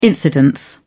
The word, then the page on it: incidence: British English